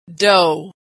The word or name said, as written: dough